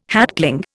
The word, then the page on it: hatchling: Indian